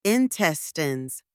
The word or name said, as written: intestines